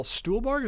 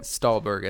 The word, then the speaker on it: Stuhlbarg: And I definitely want to know more about Michael Stuhlbarg, is that how you say it? – Stahlberg, I think.